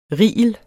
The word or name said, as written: rigel